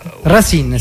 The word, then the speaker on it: Racine: No, Racine si pronuncia, perché il nome è francese e significa radici.